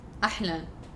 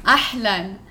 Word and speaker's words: Ahlan: It's not Ahla. – What is it? – Ahla means like Ahla Sahla. – No. – That's what you just said. – No. – Ahlan. – Ahlan. – Not Ahlan. Ahlan. – Ahlan. – Yeah. – I was pronunciating the N, okay?